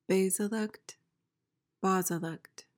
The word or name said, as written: basilect